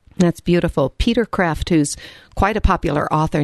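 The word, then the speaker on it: Kreeft: And lastly, I just started a book called How to Be Holy by Peter Kreft. – Mm-hmm. – Is that how you pronounce his yes. – name? – Yes.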